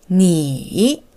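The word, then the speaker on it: 니 (你): 니 N I 삼성이고요.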